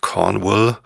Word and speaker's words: Cornwall: Da geht's heute natürlich weiter mit der UK-Reise in Cornwall. Ich habe eine sehr nette E-Mail bekommen, wo mir erklärt worden ist, dass Cornwall eigentlich Wall ausgesprochen wird und nicht Will.